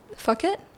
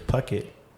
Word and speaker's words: Phuket: Fuck it? – Yeah. – Uh-huh. – But that's what I thought it was how you pronounce it. But of course, it's Phuket. Or Phuket.